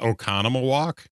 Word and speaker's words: Oconomowoc: So if you think about Oconomowoc, Oconomowoc. So two of them are pronounced long and the other three are pronounced as short O's.